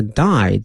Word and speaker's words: dye: 好，那么看一下这个靛蓝这个词英文的翻译哈，这 indigo I-N-D-I-G-O 后面加一个 dye D-Y-E 合到一起呢叫做靛蓝的染料或者染布的这种方式。后面这个是 D-Y-E 呢，它的发音跟这个 dye D-I-E 那个死亡啊发音是一样的，但是呢这个词表示染色的意思哈。